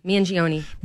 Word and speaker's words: Mangione: And so we are seeing over my shoulder here, if you're looking at Fox News, that Mangione, how do you say his name? Mangione. There Mangione. – You go. Mangione.